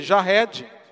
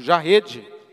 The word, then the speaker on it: Jared: Eu sou filho de Jared, ou Jared, a pronúncia você escolhe.